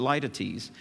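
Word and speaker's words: litotes: You may pronounce it another way, but I pronounce it Laetites.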